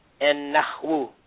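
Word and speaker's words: an-nahwu (النحو): And the technical term used in Arabic for grammar is an-nahwu. An-nahwu. Without pronouncing the dhamma on the end of the wa, it would be an-nahwu.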